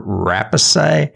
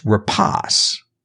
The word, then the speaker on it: Rapace: I always used to call her Rapace, but apparently I looked it up and it's Rapace.